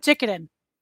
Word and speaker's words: Jikiden: Tell me how terrible did I butcher that? – That's all right. Jikidian. – Jikidian. Oh, okay. I like it.